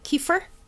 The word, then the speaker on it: kefir: She said, I'll probably pronounce it wrong. Kefir. – You're right.